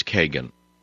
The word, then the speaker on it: Kagan: Her name is Kagan. It rhymes with pagan.